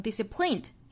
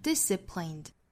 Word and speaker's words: disciplined: 最后一个词 disciplined，这个词的要点呢是重读音节在第一个音节上，所以不要读成 disciplined，应该是 disciplined disciplined，并且这个单词有一个特殊点，就是三个元音都是同样的 i 的发音，所以我们在发的时候口型应该是固定住的 disciplined disciplined disciplined。